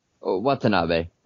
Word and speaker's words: Watanabe: Did I say that correctly? – Watanabe. Yeah, Watanabe. Close enough.